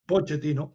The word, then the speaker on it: Pochettino: Bien, hasta ahí la palabra de Mauricio Pochettino. Vamos a traducir un poco. Perdonen que le pronuncié el nombre en italiano en vez de en español. Pochettino.